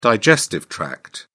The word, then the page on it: digestive tract: British English